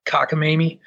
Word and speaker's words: Kotkaniemi: Nekas, and I can never pronounce this guy's name, Kotkaniemi. Yeah, Kotkaniemi.